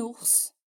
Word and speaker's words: ours: Agora eu vou te dar uma pequena listinha de palavras, as mais comuns, em que você deve pronunciar o S final. Vamos lá? Un bus, um ônibus. Un sens, um sentido. Un fils, um filho. Mars, março. Un ours, um urso.